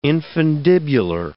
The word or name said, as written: infundibular